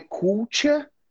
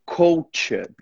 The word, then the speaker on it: culture: Não é culture, é culture. Eu ia falar, that's the correct pronunciation.